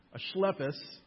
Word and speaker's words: Asclepius: It was an important religious center where the pagan cults of Athena, Asclepius, which is actually how you pronounce it, Asclepius, Dionysius, and Zeus were prominent.